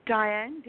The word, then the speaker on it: Diane: Diane, D-I-A-N-E.